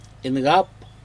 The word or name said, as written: incap